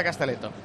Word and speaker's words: Castelletto: ¿Castelletto? Sí, ¿verdad? Se pronuncia Castelletto.